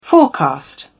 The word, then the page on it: forecast: British English